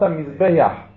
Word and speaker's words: מזבח: אתה כאילו מוסיף יוד בין הבית לחט, והיוד הזאת גונבת את הפתח לחט, והיא לוקחת את הפתח, אתה אומר מזבח יח. כאילו אמרת מזבח יח.